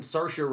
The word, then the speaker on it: Saoirse: Saoirse Ronan is, I think, is how they pronounce it. But then you look at her name, like that's not how that's spelled at all.